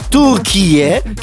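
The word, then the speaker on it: Türkiye: Nonché la pronuncia è completamente diversa da Turchie, che è la pronuncia giusta.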